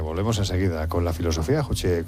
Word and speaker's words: Juche: Sí, es que lo pronuncia mal. Juche.